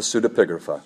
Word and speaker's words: Pseudepigrapha: The Pseudepigrapha. – Pseudepigrapha.